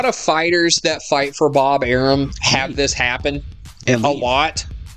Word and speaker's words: Arum: Yeah, I would say it rhymes with Bob Arum. It's Bob Arum.